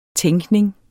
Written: tænkning

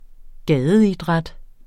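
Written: gadeidræt

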